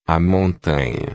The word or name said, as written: montanha